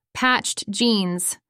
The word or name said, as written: patched jeans